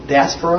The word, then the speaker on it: diaspora: There were exiles, they called them the dispersion, the diaspora, I think is a word, in the Greek. Diaspora?